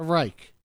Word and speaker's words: Reich: The Reich.